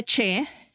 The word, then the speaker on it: Che: Esta serie de comedia se llama The Damn Michael Che, creo que se pronuncia Che.